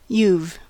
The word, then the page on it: you've: English